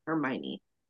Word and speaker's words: Hermione: I already know, because we're watching it at the second part, that Hermione, which does not look like that's how it's said when you read it. That is for sure. Hermione.